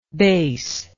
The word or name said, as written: Bes